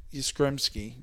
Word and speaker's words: Yastrzemski: If his grandfather actually played for the Mike Yastrzemski. You said it right the first time.